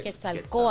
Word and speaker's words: Quetzalcoatl: How do we pronounce it? Well, it's pronounced Quetzalcoatl. Once again, I got it right. – Quetzalcoatl.